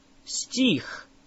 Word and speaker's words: Стих: Now, how do you say a verse? Стих. Стих. Say их. Их. Тих. Тих. Now add an S at the front of it. Стих. Стих.